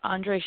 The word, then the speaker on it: Andre: So, again, my name is Andre Shakti. It's spelled A-N-D-R-E. My last name is S-H-A-K-T-I.